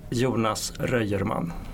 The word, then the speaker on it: Jonas H Röjerman: Jonas H Röjerman.